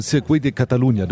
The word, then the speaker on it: Circuit de Catalunya: Also du warst auf dem Circuit de Catalunya, du warst nicht in Barcelona, nur in der Stadt oder so.